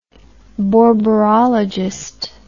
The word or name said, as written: borborologist